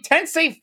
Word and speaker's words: Tensei: Sensei. – I said it wrong. Damn it. Shin Megami Tensei 5 has sold more than 1 million units worldwide.